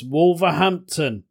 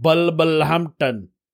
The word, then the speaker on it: Wolverhampton: Asians can't pronounce Wolverhampton properly. Many of them call it Bulbulhampton.